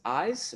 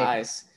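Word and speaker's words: Eysz: Today's episode, we have Dr. Rachel Cooperman from, is Eyes? Am I saying it right? – Eyes, that's right. – Eyes.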